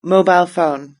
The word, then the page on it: mobile phone: British English